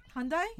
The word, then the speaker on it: Hyundai: H-U-H-H-Y-U-N-D-A-I. – Hyundai? – We say, how do you say it? – Hyundai. – Hyundai. – Yeah. – Yeah. I heard someone say Hyundai. I don't know, some weird. – No, it's Hyundai.